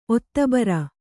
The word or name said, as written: ಒತ್ತಬರ